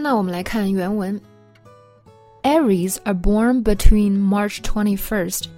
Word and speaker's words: Aries: Aries Aries，那它的这个发音呢稍微奇怪一点，就是这个 A 是吧，它发的是 red 那个小 e 的那个音，而不是梅花 i，大家要注意了。